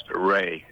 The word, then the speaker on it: Rhea: Now, it's spelled R-H-E-A. Please tell us how you pronounce your last name, counselor. – It's pronounced Ray, as though it were R-A-Y.